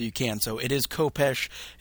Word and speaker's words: Kopecz: One of the ones that probably people will have the most trouble with, there's a Dark Lord or a Sith Lord named, his name's Kopesh. Is how Drew pronounced it, but his is the name that's spelled K-O-P-E-C-Z. So just in case people are wondering how to pronounce that, it is Kopesh.